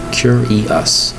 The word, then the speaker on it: curious: Three distinct syllables spelled C-U-R-I-O-U-S. Cure-e-us. Now you look at that word and you look at the C-U-R. Why isn't there a C-E-R? It's pronounced curious.